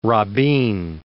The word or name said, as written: Rabin